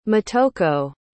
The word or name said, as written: motoko